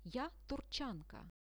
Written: ja turchanka

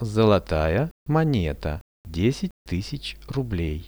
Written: zolotaja moneta 10000 rublei

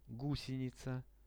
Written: gusenica

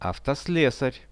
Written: avtoslesar

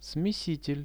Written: smesitel

